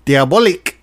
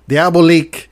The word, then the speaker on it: Diabolik: They call themselves, I hope I said this right, Diabolik. It's spelled diabolic with a YK, Diabolik.